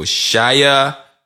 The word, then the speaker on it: Sheyaa: It's spelled S-H-E-Y-A-A. So Shia Ben Abraham Joseph. AKA 21 Savage. – It's not a bad, it's not a bad try. I'll give it to you. You got it.